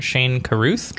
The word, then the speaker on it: Shane Carruth: Oh, the movie that we're here to talk about this week is 2013's Upstream Color, directed by, I want to make sure I'm pronouncing this right, Shane Carruth? – Correct.